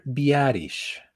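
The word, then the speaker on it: Biadasz: es que yo le digo Vallatas, pero no recuerdo cómo se debe Tyler Biarish. Biarish, ok.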